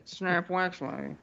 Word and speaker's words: Snap Wexley: Snap Wexley? – Tim, you got to say it right. You gotta, snap Wexley.